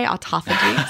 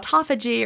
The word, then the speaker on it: autophagy: And also, autophagy is not – sorry, I said the American way, autophagy, sorry. I love your Australian pronunciation. That really is how people say it in Australia, anyone who's listening.